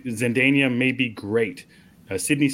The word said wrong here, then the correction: Zendaya: Zendaya, I think it was Zendaya.